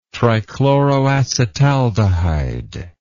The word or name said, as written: trichloroacetaldehyde